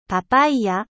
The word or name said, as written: papaye